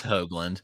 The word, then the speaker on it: Hoglund: Okay. Hoglund, Let's think Hoagie. It's a New England thing. I think Hoagie. So Gunnar Hoglund and Gavin Williams, I think, are the top two value picks here in the first round.